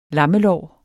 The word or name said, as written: lammelår